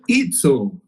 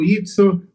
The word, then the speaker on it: Izzo: Tá aí, portanto, então, Ivan Itzo, assim a pronúncia, né? Italiano, Itzo, nosso convidado aqui do Golímpico Podcast.